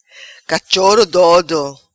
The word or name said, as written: Cachoro Doido